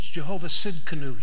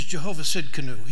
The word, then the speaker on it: Jehovah Tsidkenu: One of the Old Testament names of God, Hebrew names of God, don't try to spell it. It's Jehovah Tsidkenu. It starts with a T. But it means the Lord my righteousness. He is Jehovah Tsidkenu.